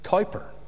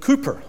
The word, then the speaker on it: Cowper: It was William Cowper, and it looks like it's spelt and therefore should be pronounced Cowper. But Cooper apparently was the pronunciation.